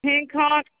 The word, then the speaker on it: Hancock: A-U-D-R-A. – And last name? – Hancock, H-A-N-C-O-C-K.